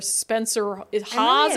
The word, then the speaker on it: Spencer Hawes: And who cares what former Sacramento Kings basketball player Spencer Hawes, am I even saying that right? – Yeah.